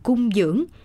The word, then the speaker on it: cúng dường: Thật ra, từ ngữ cúng dường hay đọc đúng âm là cung dưỡng,